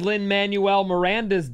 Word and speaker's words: Lin-Manuel Miranda: But of the things Lin-Manuel Miranda's done, did I get his name right? – Yeah.